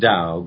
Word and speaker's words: Tao: Tao is pronounced Tao, but it is spelled in our language T-A-O.